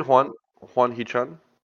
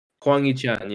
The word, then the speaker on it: Hwang Hee-chan: Is that how you say it, Hwang Hee-chan? – Hwang Hee-chan, yeah.